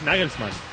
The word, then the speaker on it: Nagelsmann: Es Nagelsmann. – Claro, es Nagelsmann.